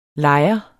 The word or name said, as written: LEJERE